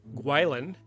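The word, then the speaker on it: gwylan: So the word gull takes its name from the Welsh word for the bird, which is gwaelen. And so that just became gull in English. And the term seagull has been around since 1540. – So the W in Welsh is pronounced oo, not W.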